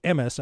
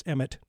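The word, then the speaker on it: Emes (אמת): But sticking with the subject of Jewish law, true Torah law, as I understand that it used to be called Emes, I guess it's Emmet now, changing pronunciation of Hebrew, meaning truth.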